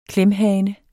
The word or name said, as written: klemhane